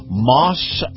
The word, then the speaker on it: Maus: Moss Acura. Moss is spelled M-E-U-S. And, of course, you heard me talk about Moss Nissan. Well, Moss Family Automotive, Moss spelled M-A-U-S, now has Moss Acura on North Florida Avenue in Tampa.